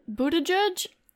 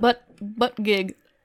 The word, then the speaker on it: Buttigieg: Buttigieg? Not how you pronounce it. – I know. I think we talked about this on our last episode, too. Or was it two episodes ago? – I don't know. Yeah, probably. But, like, it's, like, butt gig.